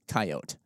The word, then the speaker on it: coyote: People mentioned coyote style. Coyote. – That's, yes. – I've been saying it wrong.